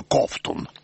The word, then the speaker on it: Ковтун: Кузнецова стала Плотниковой, Зуров – Гуровым, а Маргу наделили фамилией Ковтун, да еще с ударением на первом слоге – Ковтун.